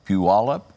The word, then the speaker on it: Puyallup: Puyallup? – Sounds good to me.